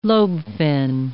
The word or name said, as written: lobe-fin